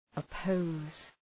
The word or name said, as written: oppose